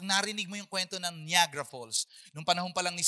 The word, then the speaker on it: Niagara Falls: This is called, ang pronunciation ko niyan ay Niagara Falls. Di ba? Ganun tayo, Niagara Falls. Pagdating pala, yung totoo palang pronunciation niyan ay Niagara Falls.